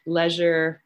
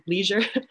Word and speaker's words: leisure: We want to talk about your 2012 book, Deafness, Community and Culture in Britain, Leisure, or to use a American/Canadian pronunciation, Leisure and Cohesion, 1945-95.